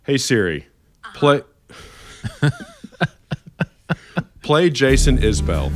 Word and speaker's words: Isbell: By the way, it's not Jason Isbell. It's actually Jason Isbell, I learned.